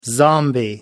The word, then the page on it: zombie: American English